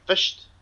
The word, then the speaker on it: Fisht: F-I-S-H-T. Fished.